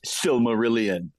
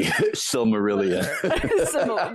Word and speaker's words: Silmarillion: It's pronounced Silmarillion, Emily. Silmarillion.